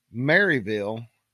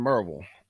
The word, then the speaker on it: Maryville: And he always explains that the reason he says Maryville is because people who ain't from around here don't understand Merville. And so he always pronunciates Maryville, for those that ain't from around here. But if you are from around here, it's Merville.